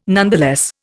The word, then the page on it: nonetheless: Indian